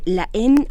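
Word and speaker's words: Haine: La En o El Odio, o como ustedes lo quieran, se escribe La Aine la AINE con H, la EN. E.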